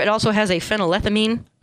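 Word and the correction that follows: phenylethylamine: Phenylethiamine. – Sorry, I missed one of the syllables.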